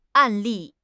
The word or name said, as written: 案例